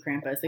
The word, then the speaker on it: Krampus: I looked up the pronunciation. It is Krampus. Mm-hmm. Even though in the movie she says Krampus. – No. – Like crump. – No. – Cramp. It is Krampus. Like a cramp.